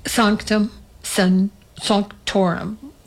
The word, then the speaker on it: Sanctum Sanctorum: I am happy to say I've got an exit strategy, Sanctum Sanctorum, or as Constance Demby told, she doesn't want to pronounce that way. She wants it Sanctum Sanctorum. Okay, she's not from upstate New York. What can I say? Sanctum Sanctorum.